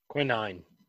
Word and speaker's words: quinine: Q-U-I. – Qui. – And then nine. – Quinine.